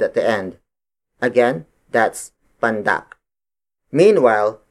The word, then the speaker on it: pandak: The opposite of tangkad is pandak. Pandak. Pandak. Pandak is spelled as P-A-N-D-A-K.